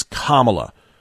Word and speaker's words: Kamala: Remember, it's Kamala like communist, not Kamala like capitalist.